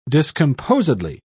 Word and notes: discomposedly: British English

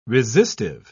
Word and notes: resistive: British English

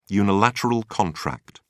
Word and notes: unilateral contract: British English

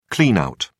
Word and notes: clean out: British English

